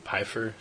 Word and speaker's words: Pfeiffer: Hilary Barta pronounced it Pfeiffer, so yeah. I guess that's how it is. – I think that's how it actually is pronounced.